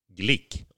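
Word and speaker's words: Glück: Och Bynger uttalas Y och Glick uttalas Glick och inte Glyck.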